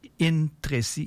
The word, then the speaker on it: Intrecci: Capezzuoli, ça s'écrit C-A-P-E-Z-Z-U-O-L-I. Et c'est un Italien. Alors, le titre de son album, c'est Intreci, I-N-T-R-E-C-C-I.